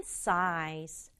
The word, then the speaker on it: size: 첫 번째 단어는 size. size예요. 이거 들어봤잖아요. 우리말로도 size 이렇게 얘기하잖아요. size가 뭐야? 근데 우리말은 size 세 박자지만 영어는 size. size 한 박자로 발음한다.